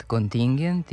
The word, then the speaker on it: kontingent: Delinkvent, kontingent i korespondent imaju kratko uzlazni akcenat na pretposlednjem slogu, jer prema pravilima naše akcentuacije poslednji slog ne može biti akcentovan.